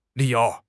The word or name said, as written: li3 you2